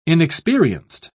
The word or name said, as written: inexperienced